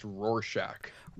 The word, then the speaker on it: Rorschach: It's pronounced Rorschach.